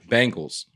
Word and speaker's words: Bengals: Since I actually sided with him in the penguin pronunciation, I find it funny to hear it pronounced Bingles. as if it rhymes with Pringles.